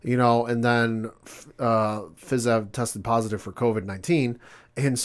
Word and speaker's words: Fiziev: Yeah, so this fight was originally supposed to be between Dos Anjos and Rafael Fiziev, I think is how you say it. Fiziev, yeah.